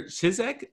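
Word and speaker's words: Ciszek: He Leadeth Me by Father Walter Chizek. Is that how you say it? – Yeah.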